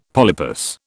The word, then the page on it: polypus: British English